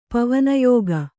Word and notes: ಪವನಯೋಗ: pavana yōga

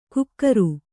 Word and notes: ಕುಕ್ಕರು: kukkaru

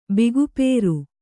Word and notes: ಬಿಗುಪೇರು: bigupēru

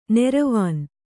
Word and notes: ನೆರವಾನ್: neravān

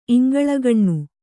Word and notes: ಇಂಗಳಗಾಣ್: iŋgaḷagāṇ

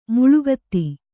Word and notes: ಮೇಳಗೀತ: mēḷa gīta

